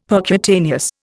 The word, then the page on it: percutaneous: Indian